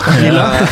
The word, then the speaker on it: Villa: Villa. – Man uttaler faktisk L-ene på engelsk.